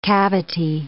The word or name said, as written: cavity